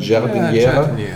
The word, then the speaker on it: Giardiniera: Wie spricht man so aus? Du warst gerade in Italien? – Giardiniera? Giardiniera. Und weißt du, dass das übrigens ein, äh, ein, ein, ein, ähm, wie soll man das sagen, zukunftsweisendes Konzept war?